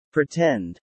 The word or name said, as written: pretend